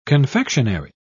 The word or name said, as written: confectionary